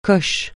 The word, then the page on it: coche: francais